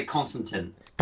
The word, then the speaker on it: Konstantin: Is it Konstantin or Constantin? In French, we'd say Constantin, but how would you say it? – I'm not French, so I would say Constantin.